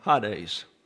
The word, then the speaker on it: Hades: And the Ada, there are two E's in Greek, and the Ada is pronounced like a long A. So you see the E there, but you pronounce it as if it were a long A, Hades, and the accent's on the first syllable.